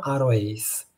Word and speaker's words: aroace: però si scrive ace perché in inglese ace si legge ice e quindi diventa il suono aroace, che sarebbe aromantic asexual.